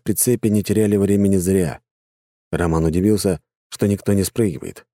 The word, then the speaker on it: Роман: «Роман! Роман!» — делая ударение на первый слог, подбежал водитель и сунул Роману телефон.